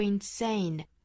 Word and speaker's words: insane: 那么我们继续看 go insane, insane 的重读音节呢是在后面的这个 ain 上面哈 sane 上面，所以是 go insane, da da da, da da da 这样的节奏。